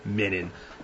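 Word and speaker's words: mignon: It's minin. Jesus, you're a freaking $50 million net worth TV chef. Learn how to pronounce the word.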